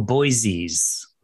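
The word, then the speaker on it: O'Boisies: O-B-O-Y? – O apostrophe B O I S I E S, like Boise, Idaho. – I see. Oh, Boise's.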